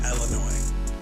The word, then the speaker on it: Illinois: And first off, I just want to start off by saying it is pronounced Illinois. There is no S pronunciation for the Illinois, so you don't say Illinois. It's no Illinois. This doesn't exist. This doesn't exist. This is a common myth and a common mispronunciation of the state of Illinois.